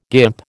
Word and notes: guimp: American English

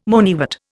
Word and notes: moneywort: Indian

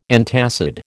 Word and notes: antacid: American English

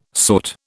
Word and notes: sought: British English

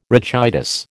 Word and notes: rachitis: American English

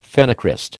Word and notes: phenocryst: American English

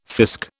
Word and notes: fisk: British English

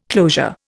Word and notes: closure: Indian

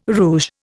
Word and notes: rouge: Indian

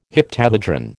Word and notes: heptahedron: American English

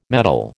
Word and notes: metol: American English

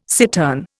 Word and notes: cittern: Indian

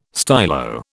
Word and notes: stylo: British English

